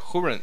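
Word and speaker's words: Húrin: Je les ai pas lus, ces livres-là, là, malheureusement, mais ce livre-là, qui est The Children of Urin... – Ouais. Urin, je pense. – Urin. Hurin. C'est H-U-R-I-N. – Ça, je pense que ça se prononce Hurin.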